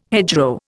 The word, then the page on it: hedgerow: Indian